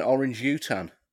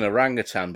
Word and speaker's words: orangutan: It's an orangutan, but that's how I used to pronounce it when I was a kid, when I just read it.